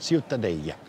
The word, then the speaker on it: Ciutadella: Aber Ciutadella, richtig ausgesprochen? – Ja, das Städtchen, Ciutadella.